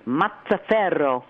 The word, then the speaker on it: Mazzaferro: And remember before we got on, I said the way you pronounce this in Italy is Mazzaferro.